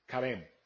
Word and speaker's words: Charem: Se llama karem. Karem. C-H-A-R-E-M. Karem. De ahí viene el nombre karem. Okay, muy parecido, karem. Porque este CH, la CH se pronuncia de esa manera.